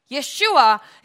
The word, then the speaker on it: Yeshua: Yeshua is the name, that's the pronunciation in the original name, which means the Savior, he who saves.